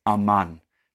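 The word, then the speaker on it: aman (אָמַן): In Hebrew, it is aman. That's how you say it.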